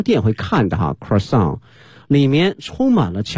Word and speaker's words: croissant: It's a chocolate croissant with rich chocolate inside and outside。